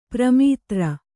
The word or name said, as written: ಪ್ರಮಾತೃ